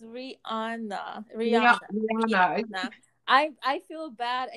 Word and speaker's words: Rihanna: It's Rihanna. Like Rihannuh. That's how you should say it.